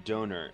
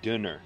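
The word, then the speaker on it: döner: I've heard that pronounced donor, or like dinner.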